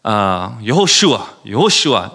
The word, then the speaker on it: Yehoshua: But over time, so there was over 1,000 times, so 1,000 years, if you say Yehoshua really fast, and the accent is on Shua, right? Yehoshua.